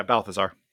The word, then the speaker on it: Balthazar: You know, even the battle of between Matthias and is it Baltzar? How does he pronounce it again? Michael Clark Balthazar. Duncan's character. – Yeah, Balthazar.